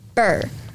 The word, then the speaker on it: Burr: It's literally called Burr. Literally. – Burr, it's cold in here. – Yeah, exactly. That that burr. – It's spelled B-U-R-R.